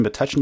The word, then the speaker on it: Metuchen: But speaking of growing up and being named and all that, you grew up in Metuchen, New Jersey. – Correct, yeah. You pronounced it correctly.